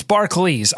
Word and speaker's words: Barclays: The food is, first of all, it's Barclays. I checked the pronunciation guide. The NBA pronunciation guide. It's apparently Barclays, not Barclays. So I wanted to get that right.